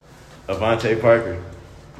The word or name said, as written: Avantae Parker